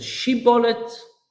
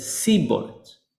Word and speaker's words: shibboleth: One of them said shibboleth, and one of them said sibboleth.